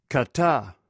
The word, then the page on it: kata: greek